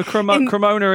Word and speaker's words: Cremona: No, Cremona is correct.